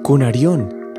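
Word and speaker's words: kunarion (κυνάριον): Y aparte, el diminutivo usado a continuación, perrillo, que en griego se pronuncia kunarion, la cual hace referencia a un cachorro, lo que podría ser una mascota, que es como ella lo entendió.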